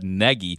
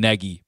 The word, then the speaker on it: Nagy: Texter, you're wrong because Matt Nagy himself has pronounced his name both Nagy and Nagy before. So Strictly speaking, his last name is pronounced Nagy. He accepts both.